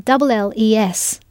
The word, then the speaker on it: travailles: If you were writing this sentence, you would be spelling the tu form of the verb as travailles. In this tu form, you need to add the s, although you do not hear it pronounced.